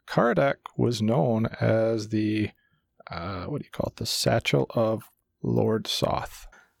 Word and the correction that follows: seneschal: I may have said it wrong. Sight. – Seneschal.